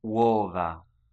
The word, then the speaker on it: uova: Uova.